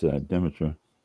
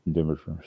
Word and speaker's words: Demeter: Demeter, I'm not sure how they pronounce it. I think it's Demeter, D-E-M-E-T-E-R.